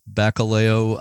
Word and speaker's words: Bacalhau: Moving ahead here, you your next station was Bacalhau Island. Am I pronouncing that right? – Yes, Bacalhau. More or less, which I think comes from Portuguese for cod, right? – It's a Portuguese name, yes.